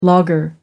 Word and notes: logger: American English

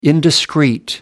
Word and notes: indiscreet: American English

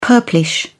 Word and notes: purplish: British English

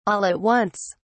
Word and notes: all at once: British English

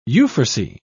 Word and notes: euphrasy: British English

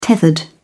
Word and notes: tethered: British English

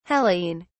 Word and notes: hellene: British English

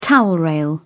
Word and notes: towel rail: British English

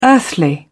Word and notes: earthly: British English

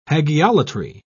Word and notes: hagiolatry: British English

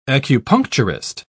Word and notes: acupuncturist: British English

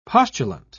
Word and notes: postulant: British English

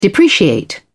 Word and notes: depreciate: British English

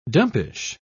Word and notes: dumpish: British English

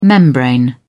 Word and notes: membrane: American English

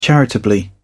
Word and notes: charitably: British English

